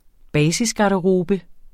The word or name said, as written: basisgarderobe